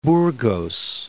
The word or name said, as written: Burgos